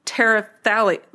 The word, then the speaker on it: terephthalate: Terephthalate, yeah. No, that's it.